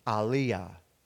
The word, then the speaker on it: Elijah: And Elijah, the prophet, his name is pronounced in Hebrew, Aliyah.